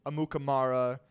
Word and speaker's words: Amukamara: They posted a picture on Twitter with Mitch Trubisky, Prince Amukamara.